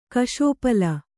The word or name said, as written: ಕಷೋಪಲ